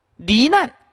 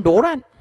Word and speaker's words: 罹难: 罹难他念成罗难，破绽念破定，这一直念错啊啊，浪费功奴。